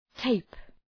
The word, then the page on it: tape: teıp